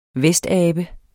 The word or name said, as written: vestabe